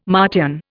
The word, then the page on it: Martian: Indian